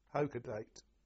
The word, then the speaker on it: Hakodate: But from there, we spent 12 days there, and then we went to the city and port of Hokodate, which is spelt H-A-K-O-D-A-L. T-E. – O-D-A-L-T-E. – Yeah. H-A-K-O-D-A-T-E. Hokodate. – Now, where's that? Near... – That's near Ominato. It's up north Japan. One of the northernmost ports, Hokodate.